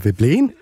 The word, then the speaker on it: Veblen: Det er Veblen.